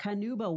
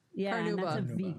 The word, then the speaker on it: carnauba: Carnauba? – Carnauba wax. – Yeah. – Carnauba.